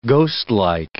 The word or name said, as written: ghostlike